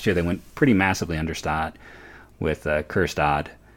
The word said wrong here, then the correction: Kjerstad: and, I'm sorry, Kjerstad. You don't pronounce the K.